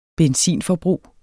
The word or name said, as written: benytte